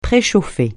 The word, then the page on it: préchauffer: francais